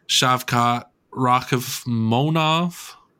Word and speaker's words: Shavkat Rakhmonov: And for the third to last fight in the main card, it's going to be Geoff Neal and Shavkat Rakhmonov. – You definitely said that right.